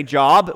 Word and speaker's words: Job: Maybe you're curious, why is this three-letter word, Job, pronounced one way in reference to a man that lived long ago, and it's pronounced another way in reference to our professions, as in we say job when we're talking about where we work, but we say Job when we're talking about really only when we're talking about this book of the Bible.